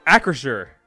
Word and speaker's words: Acrisure: First up, the Steelers changed their name, from Heinz Field to, how do you say this, like Acrisure Stadium? Ass Stadium? What is this? – Acrisure.